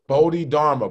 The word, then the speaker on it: Bodhidharma: Bodhidharma.